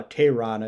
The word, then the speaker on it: Teheran: He was high a few years, but number one in front of him in different years were Tommy Hanson and Julio uh, Teheran, I believe is the correct pronunciation.